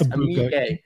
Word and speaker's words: Egbuka: Might be able to pick up no, not Marvin Harrison Jr., but maybe his running mate, Egbuka. I know I probably messed that up, so I'm sorry. Bob taught me how to. Yes, Egbuka.